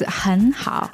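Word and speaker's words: hen hao (很好): Is that the proper way to say it? Hen hao? – Yes, hen hao. – Oh, so even though in the pinyin it's an E, it's not pronounced like the English E so much, right? – Right.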